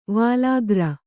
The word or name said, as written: ವಲಾಗ್ರ